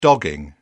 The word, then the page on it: dogging: British English